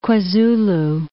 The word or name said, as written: KwaZulu